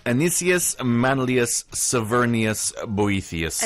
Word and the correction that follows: Severinus: Oh, yeah, I'm mispronouncing it. Severinus. I've been mispronouncing that, actually. I just realized.